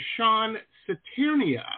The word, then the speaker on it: Cisterna: The name of the documentary is called The Long Rider, and our guest today is the director of The Long Rider, Mr. Sean Cisterna. Hopefully I'm saying that right. – Yeah, close enough. Cisterna. It's Sean Cisterna, but close enough.